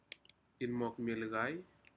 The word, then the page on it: inmokmilcai: Aneityum